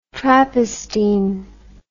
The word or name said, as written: trappistine